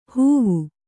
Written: ಹೂವು

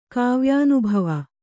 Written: ಕಾವ್ಯಾನುಭವ

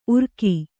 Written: ಉರುಗು

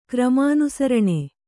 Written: ಕ್ರಮಾನುಸರಣೆ

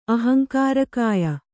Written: ಅಹಂಕಾರಕಾಯ

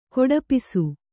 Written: ಕೊಡಪಿಸು

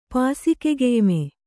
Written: ಪಾಸಿಕೆಗೆಯ್ಮೆ

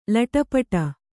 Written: ಲೊಟಪಟ

